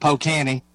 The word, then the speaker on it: Polk County: Of course, from God's country, as I like to call it, Polk County, Tennessee. – Beautiful Polk County. – Yeah, or as the locals pronounce it, Pocanny.